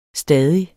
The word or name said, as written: stadig